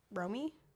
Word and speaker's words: Romy: The next one is by Death by Rami. Romy? Death by Romy? – Never heard. – R-O-M-Y.